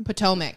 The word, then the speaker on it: Potomac: Housewives of Potomac. Am I saying that right? – Potomac. – Yep. – Potomac? Yeah.